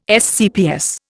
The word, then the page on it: SCPS: Indian